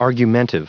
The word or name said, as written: argumentive